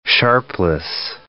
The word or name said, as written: Sharpless